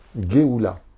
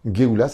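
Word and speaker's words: Geoula (גאולה): La libération, la rédemption, comment on le dit en hébreu Geoula. C'est un truc de fou. Geoula, ça s'écrit Gimel, Aleph, Vav, Lamed, He.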